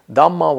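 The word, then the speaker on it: dhammā: One of them is it's a plural with a long A. It's not the dhamma of the Buddha. That would be his teaching, but it's the dhamma with in the sense of phenomena, states, but content of mind, thought, concepts, ideas, any object of mind is a dhamma.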